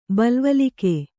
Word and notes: ಬಲ್ವಲಿಕೆ: balvalike

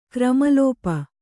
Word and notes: ಕ್ರಮಲೋಪ: Kannada

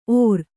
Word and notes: ಓರ್: ōr

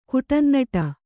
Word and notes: ಕುಟನ್ನಟ: kuṭannaṭa

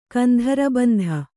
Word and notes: ಕೇಂದ್ರಬಿಂದು: kēndra bindu

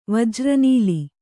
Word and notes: ವಜ್ರನೀಲಿ: Kannada

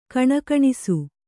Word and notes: ಗುಣಗುಣಿಸು: Kannada

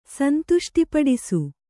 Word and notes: ಸಂತುಷ್ಟಿಪಡಿಸು: kannada

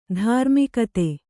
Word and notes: ಧರ್ಮಗುತ್ತಿ: dharma gutti

